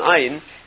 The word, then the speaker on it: ayin: Ayin is pronounced. Anybody who speaks Hebrew correctly, you can hear the ayin. It's one of the guttural letters. It's not a silent letter.